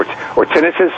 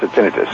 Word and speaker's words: tinnitus: Now, do you know what tinnitus is? – Yes. – Or tinnitus, sometimes pronounced tinnitus. – Yeah, no, I do tinnitus. That's what I do, yes.